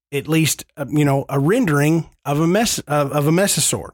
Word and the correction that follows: mosasaur: Mosasaur. – That. – You said it right. – Mosasaur, mesosaur. – You said it right the first time.